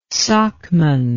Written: socman

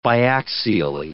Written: biaxially

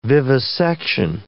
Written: vivisection

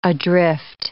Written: adrift

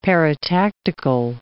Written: paratactical

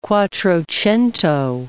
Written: quattrocento

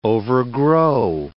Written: overgrow

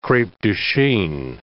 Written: crepe de chine

